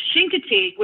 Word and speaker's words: Chincoteague: Actually, Chincoteague, which is how it's pronounced,